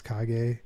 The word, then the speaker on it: Kage: So we didn't really touch base upon the techno and house stuff I do, which is my other name, Brian Kage with a K. It's pronounced Kage.